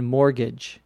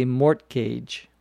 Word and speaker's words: mortgage: That's loan has a special name, which is a mortgage, M-O-R-T-G-A-G-E. Notice that we don't pronounce the T in mortgage. You don't say mortgage. We say mortgage. But it is spelled with a T in the middle.